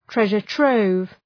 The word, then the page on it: treasure trove: ‘treʒər,trəʋv